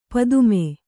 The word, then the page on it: ಪತಮ: patam